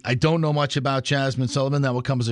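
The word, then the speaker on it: Jazmine: Jasmine Sullivan, I may be saying that wrong. Is it Jasmine? – Nope, Jasmine. – Jasmine Sullivan. – Yep.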